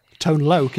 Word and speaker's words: Tone Loc: Tone Loc, is that how we pronounce it, is it? – Yeah.